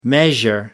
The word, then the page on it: measure up: American English